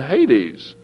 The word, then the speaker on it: Hades: it says that he lifted up his eyes in Hades. That's how they say it.